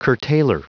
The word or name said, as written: curtailer